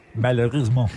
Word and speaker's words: Malheureusement: Malheureusement.